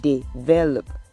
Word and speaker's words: develop: The word is develop. It sounds The stress is on the second syllable.